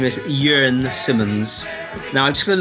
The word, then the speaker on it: Jörn: Jörn. I'm going to spell it. So Jörn is spelled J-U-R-N.